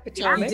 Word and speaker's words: Potomac: Potomac.